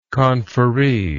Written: conferree

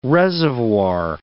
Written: reservoir

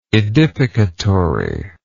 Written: edificatory

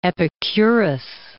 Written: Epicurus

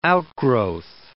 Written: outgrowth

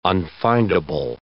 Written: unfindable

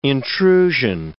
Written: intrusion

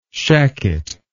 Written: shacket

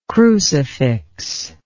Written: crucificial

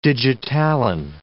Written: digitalin

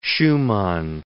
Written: Schumann

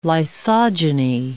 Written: lysogeny

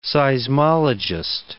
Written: seismologist

